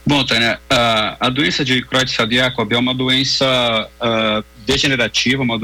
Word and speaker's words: Creutzfeldt-Jakob: É a doença, vou ver se estou acertando a pronúncia, é Creutzfeldt-Jakob. Nós vamos conversar com o neurologista e neurocirurgião. Muito boa noite. – Boa noite, Tânia. – É essa mesma a pronúncia? Pra gente... – É, Creutzfeldt-Jakob, tanto faz.